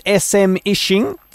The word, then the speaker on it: smishing: What exactly is SMishing? Am I saying that right? And who does it affect? – So there's no perfect way to say it, but smishing is what some people say.